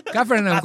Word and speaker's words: Capernaum: Capernaum, con acento en la A, dirigida por Nadine Lavaki.